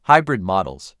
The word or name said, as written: hybrid models